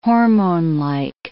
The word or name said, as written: hormonelike